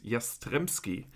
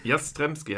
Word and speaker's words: Jastrzemski: Wie spricht man ihn aus? Dennis heißt er mit Vornamen. – Aber Dennis heißt er mit Vornamen, das war der einfache Part. Und der Nachname ist Jastrzemski. – Jastrzemski haben wir von Tino Polster gelernt, der es von Dennis Jastrzemski gelernt hat.